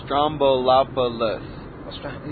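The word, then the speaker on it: Stroumboulopoulos: Strombolopoulos. – You know, I put an N in there. I went Stramanopoulos. No, Strombolop. I still need some work.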